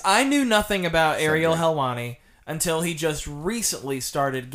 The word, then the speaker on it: Helwani: I pronounced that right, Helwani.